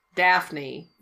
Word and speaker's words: Daphne: Daphne, am I saying that right? – Yes.